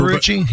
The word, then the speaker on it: Marucci: Mariucci is the one that they have. Is that, am I saying that right? I can't remember. – Marucci, yes.